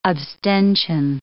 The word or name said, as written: abstention